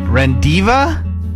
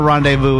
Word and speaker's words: rendezvous: R-E-N-D-E-Z-V-O-U-S. R-E-N-D-E-Z-V-O-U-S. – Rendiva?